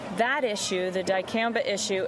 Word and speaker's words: dicamba: Is that, did I say it wrong? – It's dicamba.